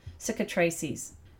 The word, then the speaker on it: cicatrices: So, what it's a word in the materia medica, and it's called cicatrices. And it's spelled C-I-C-A-T-R-I-C-E-S. And again, like I said, it's cicatrices.